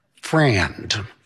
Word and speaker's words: friend: When spoken as Southern code, it is typically pronounced as a two-syllable word, friend.